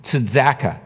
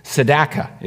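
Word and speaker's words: Tzedakah (צדקה): And righteousness is the word tzedakah. I'm not a Hebrew scholar, so you're going to have to bear with my pronunciation. Tzedakah is how you say it.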